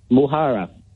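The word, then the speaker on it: mo chara: That's Muhara. That's how you say the Irish name that you couldn't pronounce.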